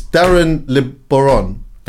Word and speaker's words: Darren Le Baron: It's Darren LeBaron.